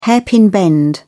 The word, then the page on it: hairpin bend: British English